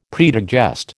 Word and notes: predigest: American English

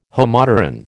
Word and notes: Homopteran: American English

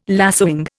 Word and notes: lacewing: Indian

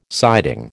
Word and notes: siding: American English

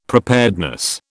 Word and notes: preparedness: British English

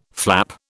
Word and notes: flap: British English

